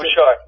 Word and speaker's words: Bouchard: You had come up with an original score by Chris, is it Bouchard? Is that how you pronounce Bouchard. – It? – Bouchard. Yeah, Chris Bouchard.